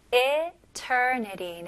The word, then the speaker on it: eternity: 네, eternity. 네, 스펠링은 E지만 I로 생각하시면 좋을 것 같아요. eternity. 네리. 마지막 T는 아주 가벼운 D로 발음하시면 훨씬 더 쉽게 발음할 수 있어요.